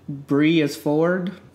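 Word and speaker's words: Breisford: My next Twitter me this is by Dylan Breeis Ford. It's spelled like Bree, B-R-E, is I-S, Ford, F-O-R-D.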